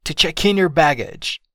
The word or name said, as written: to check in your baggage